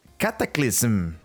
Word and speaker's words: Cataclysm: Y bueno, seguimos también con World of Warcraft, que ahora con su versión Classic le está agregando también la expansión Cataclysm. – Ok, Creo que lo dije bien, ¿no? – Sí, lo dijiste bien. Muy, muy bien por ti.